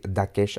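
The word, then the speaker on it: Thakesh: Alors, c'est écrit T-H-A, plus loin, K-E-S-H. Donc, je vais prononcer Dakesh un peu à l'anglaise, mais pas trop non plus.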